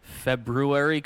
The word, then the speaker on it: February: Also, another pet peeve of mine on this song is the way he pronounces February correctly. I don't like when people do that. – That's a Canadian thing. – Swinging. – With me till February. I know there's an R in there.